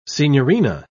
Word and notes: signorina: British English

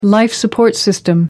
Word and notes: life-support system: American English

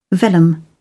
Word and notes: vellum: British English

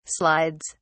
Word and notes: slides: British English

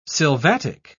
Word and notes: sylvatic: British English

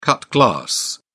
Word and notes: cut glass: British English

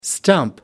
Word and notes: stump: American English

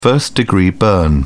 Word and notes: first-degree burn: British English

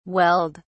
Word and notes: welled: British English

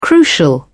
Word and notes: crucial: British English

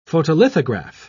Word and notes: photolithograph: British English

